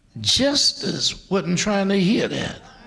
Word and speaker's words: Gestas: D-I-S-M-A-S, Dismas. Dismas was the repentant thief. On the left hand was Justice. Now it's spelled G-E-S-T-A-S, but the G is pronounced like a J, and it's Justice.